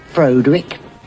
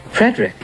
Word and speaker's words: Frederick: Do you also say Frodwick? – No, Frederick. – Well, why isn't it Frodwick Frankenstein? – It isn't. It's Frederick Frankenstein.